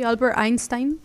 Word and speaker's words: Einstein: Sí, Albert Einstein.